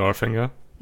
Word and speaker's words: Northanger: Northanger.